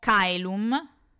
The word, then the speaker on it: caelum: Quindi quando incontreremo i dittonghi ae e oe, li leggeremo esattamente così, ae e oe. Quindi avremo Kailum e OE anche coena.